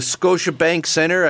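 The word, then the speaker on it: Scotiabank Centre: You said it right. The hated Habs. It's the Scotia Bank Center.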